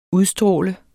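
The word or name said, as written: UDSTRÅLEDES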